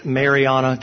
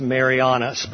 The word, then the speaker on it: Mariana: the Great Mariana Trench. Sometimes it's pronounced Marianas, but it's just south of Japan.